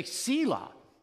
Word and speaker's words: Selah: how to pronounce that name, it's about 60-40, the 60% say Selah, but many of us say Selah.